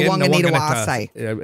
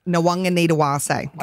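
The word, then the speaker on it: Nawaqanitawase: Nwanganitawase. – Is that right? – Nwanganitawase.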